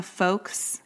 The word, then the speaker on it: folks: You really pronounce the hard L in that. – I know, folks.